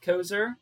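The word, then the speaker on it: Koeser: I am on Twitter at Kev Kozer.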